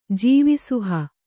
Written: ಜೀವಿಸುಹ

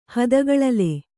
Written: ಹದಗಳಲೆ

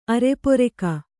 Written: ಅರೆಪೊರೆಕ

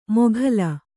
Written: ಮೊಘಲ್

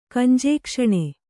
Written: ಕಂಜೇಕ್ಷಣೆ

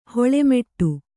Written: ಹಲ್ಲುಮೊಟ್ಟೆ

